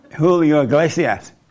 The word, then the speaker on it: Julio Iglesias: Julio Iglesias.